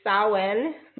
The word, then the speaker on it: Samhain: And so today, you know, like Brian said, it is Samhain. I said it right again.